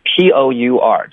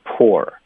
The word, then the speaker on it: pour: OK，他们的拼写有点不一样，实际上他们的发音是一模一样的，pour 就是泼水，我们就叫 pour。 Poor，然后就是贫穷是吗？就 P-O-O-R，它的发音也就是 poor， it's the same thing，没有区别。